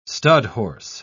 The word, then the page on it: studhorse: British English